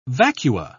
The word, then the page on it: vacua: British English